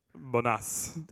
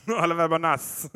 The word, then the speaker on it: Oliver Bonas: But I'm pretty sure it's Oliver Bonus. It's Bonas. – Hey, you know. I'm an American. I don't pronounce anything correct. – Oliver Bonas. – It's a good Italian boy. – It's in London. Oliver Bonas.